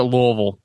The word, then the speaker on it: Louisville: You call Louisville. Oh man, you're gonna get punched in the face. You call it Louisville. – Oof. – They're gonna have a billboard out for you. But they pronounce it Louisville.